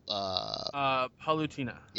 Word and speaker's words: Palutena: Palutena.